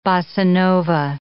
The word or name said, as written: bossa nova